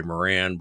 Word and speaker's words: Moran: My last name, M-O-R-A-N, in America is pronounced the English way, Moran, but in Ireland, everybody says Moran.